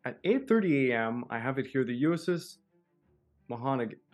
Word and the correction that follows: Monaghan: uh, Monaghan. Monahan. Monahan. Sorry, yeah, I was about to mispronounce that one pretty bad there.